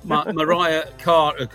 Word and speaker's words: Mariah: She's Mariah, right. By the way. We ought to pronounce her name properly. – All right. – Yeah. – All right. Thank you. Yeah. Thank you. – That's all right. – In an English pronunciation, Mariah Carey.